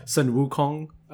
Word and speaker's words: Sun Wukong: I think when the 8 sings as part of the song too, he pronounces it the Chinese version, which is Sun Wukong.